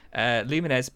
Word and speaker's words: Lumines: Lumines Puzzle Fusion. How do you pronounce it? – It's I can't even say it in the first instance. Lumines is, I believe, how it is pronounced.